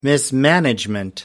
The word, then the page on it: mismanagement: American English